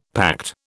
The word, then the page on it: pact: British English